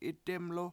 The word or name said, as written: 7itdEmlu